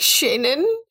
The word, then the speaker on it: Chenin: It's called, oh God, your friend Sophie is going to slit my throat, but it's spelled C-H-E-N-I-N Blanc. So it's like C-H-E-N-I-N. – Yeah. – It sounds like Shannon. – Yeah, no, so it is. It's something like that.